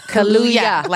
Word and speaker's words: Kaluuya: Kaluuya. – Like Hallelujah. Kaluuya.